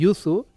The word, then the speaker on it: yuzu: i això del yuzu... les magranes, sí, magranes és un producte típicament català i un producte de la nostra terra, i que això no sorprèn a ningú, no? Però el yuzu...